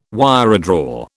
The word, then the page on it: wiredraw: British English